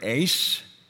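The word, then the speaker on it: eis (εἰς): It's the Greek word eis. That's how you pronounce it. It's spelled E-I-S.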